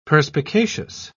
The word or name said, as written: perspicacious